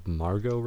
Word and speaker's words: Margot: Then we've got the one I'm most excited about, Margot Robbie, I guess is how you say her first name. Margot? – Yes.